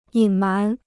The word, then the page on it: 隐瞒: yǐn mán